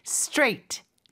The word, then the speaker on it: straight: Six consonants, but only one syllable. – 그러게요. Straight. 하나의 음절이에요. 이렇게 자음이 많지만 straight.